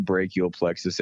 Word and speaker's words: brachial plexus: Yeah, well, for first off, it's pronounced brachial plexus injury.